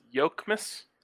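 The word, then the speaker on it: Jokić: note, should DNVR watches do a rewatch of Joke Miss once these playoffs are over? I believe it's pronounced Yoke Miss. – Yoke. Oh, yeah.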